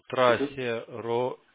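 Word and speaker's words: traceroute: Ю-Т, то есть трассе-роут читается, то есть... – Через Y. – Не Y, U, это up. – А, U, все, роут.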